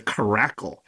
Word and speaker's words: Caracle: da da da da da da da Crackle. And it's not, it sounds like crackle, but it's spelled caracle.